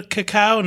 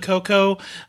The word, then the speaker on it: cacao: I'm going to say creme de cacao, not creme de cocoa. I did a lot of uh, searching around on the per- correct pronunciation, and I'm just going to go with Jacques Torres, who says cocoa means nothing to me. So it's cacao for here on out.